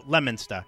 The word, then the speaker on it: Leominster: Yeah, they say Leo. It's spelled L-E-O. – There's a lot of towns in Massachusetts Leominster. – That are spelled. No, Leominster. – Leominster. – Pretend the O isn't even there.